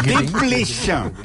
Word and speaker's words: Desplechin: Arnaud Mira, Desplecha. – Ja ho està dient bé. – Ai, de, Arnaud de, des... Desple... No, la S no es pronuncia.